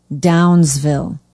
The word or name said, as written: downsville